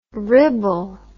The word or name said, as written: ribble